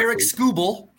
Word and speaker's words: Tarik Skubal: And they will see the Tigers' best pitcher, Tarik Skubal, And I don't even know if I'm pronouncing that correctly. – Tarek Skubal, yes. – Tarek Skubal, so I was close.